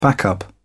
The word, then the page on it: backup: British English